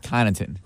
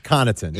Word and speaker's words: Connaughton: I actually think it's pronounced Connaughton. – And whatever it means. – It's Connaughton.